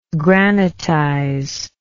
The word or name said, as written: granitize